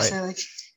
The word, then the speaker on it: Skrillex: Do you like Skrillex? I don't know if I'm pronouncing it right. Skrillex. Okay. – Yeah. Skrillex, yeah. – Skrillex, that's it.